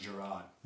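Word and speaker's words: Jerrod: So we received several comments about the stand-up comedy special of Jared Carmichael. – It's pronounced Gerard.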